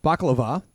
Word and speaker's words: Bakalova: And I'm going to butcher her name by Maria Bakalova.